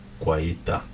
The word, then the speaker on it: Guaita: how do you say his name Kuwaita Guaita. Guaita. Vicente Guaita.